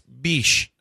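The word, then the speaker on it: Bitche: Fluent French. It's biche. – Or biche. It could be biche. – Biche. – It's not bitch.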